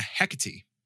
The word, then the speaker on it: Hecate: Hecate. – Yeah. – Which is, I don't know, thank you. Kelsey was like, you said it correct, because I made a note in my phone to make sure I pronounced it correctly.